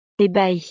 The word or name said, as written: ebahie